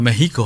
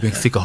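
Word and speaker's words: México: এইটা নিয়ে কলেজে পড়তে আমাদের মধ্যে বেশ তর্ক বিতর্ক হতো, যারা একটু আধটু জানতো তারা বারবার বলতো ওটা মেহিকো হবে, মেক্সিকো হবে না।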